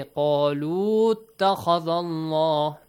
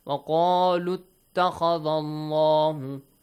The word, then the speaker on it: وقالوا اتخذ الله: We don't say قالوا اتخذ الله, no, shorten the U sound. وقالوا اتخذ الله, وقالوا اتخذ الله, simple, okay, shorten the sound.